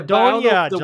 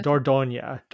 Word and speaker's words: Dordogne: Dordogne, John. It's Dordogne. Dordogne. – The Dordogne. – You know how to spell French? Do you know? – Dordogne. Dordogne.